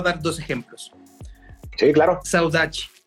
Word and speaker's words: saudade: El portugués tiene saudachi, saudachi se escribe saudade.